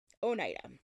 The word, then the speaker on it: Oneida: Side note, it is pronounced Oneida, not Oneida, like many out there say. Like, I watched so many videos that said Oneida.